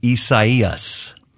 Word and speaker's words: Isaias: And it's correctly pronounced Isaias. Isaias.